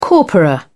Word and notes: corpora: British English

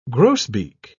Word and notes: grosbeak: British English

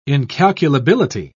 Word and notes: incalculability: British English

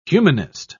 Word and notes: humanist: British English

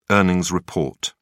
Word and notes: earnings report: British English

